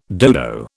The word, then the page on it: dodo: British English